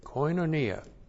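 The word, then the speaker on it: koinonia (κοινωνία): Looks like that in the Greek, K-O-I-N, long O-N-I-A, koinonia.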